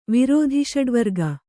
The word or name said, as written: ವಿರೋಧಿಷಡ್ವರ್ಗ